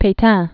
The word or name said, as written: Petain